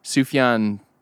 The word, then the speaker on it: Sufjan: And somebody on stage said, Sufjan, said something about Sufjan.